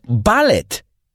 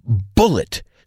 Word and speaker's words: bullet: Suelo oír a la gente pronunciar bullet, bala, mal, diciendo ballet. La palabra bullet se pronuncia bullet. So bite the bullet and say it right.